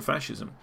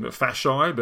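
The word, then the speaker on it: fasces: And I was calling them fasci, but apparently that's an incorrect pronunciation. And Matthew was very nice to send me a link to how it was actually pronounced. It's like fasci, I think it's pronounced.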